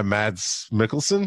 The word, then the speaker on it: Mads Mikkelsen: Overall, great performance by Mads Mikkelsen.